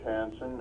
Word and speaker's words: Hansen: I'm Dr. George Hansen, and I speak as the president of the Alaska Landlords and Property Managers Association.